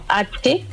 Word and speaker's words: Hanelsem: Nos pueden encontrar como Hanelsem. La pronunciación correcta es Hanelsem y nos van a encontrar con ese nombre en todo lado.